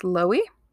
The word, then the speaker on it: Lowy: The judge, David, I'm going to say it's pronounced Lowy. It's literally low with a Y.